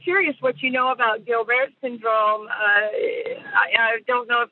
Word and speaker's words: Gilbert: It's Gilbert. It's French.